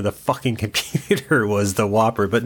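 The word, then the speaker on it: WOPR: There is no E. It's W-O-P-R. It's pronounced Whopper.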